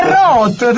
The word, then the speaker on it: Roth: Sì, ma ho i miei dubbi che si pronunci Roth. – È Roth, te lo giuro.